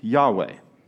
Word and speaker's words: Yahweh: It represents the Hebrew tetragrammaton we pronounce or transliterate as Yahweh.